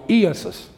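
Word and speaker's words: Iesous: Iesus, E-A-I-S-U-S, something like it. And it's an E, and the E and the A are long. Iesus is the way you pronounce it.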